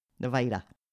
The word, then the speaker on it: Nevada: Nevada. – You say it like Darth Vader. – Nevada.